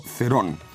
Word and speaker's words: Cerón: Este Santiago Cerón es con C, digamos que pronunciado en español correcto sería Santiago Cerón.